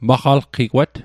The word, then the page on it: maqalqigwat: Mi'gmaq